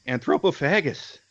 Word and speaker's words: Anthropophagus: Perhaps his most famous film for most people is Anthropophagic. Watch me mispronounce this. – Anthropophagus. – That thing, yes.